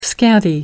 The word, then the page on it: scanty: American English